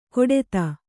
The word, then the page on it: ಕೊಡೆತ: Kannada